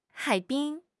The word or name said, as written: 海滨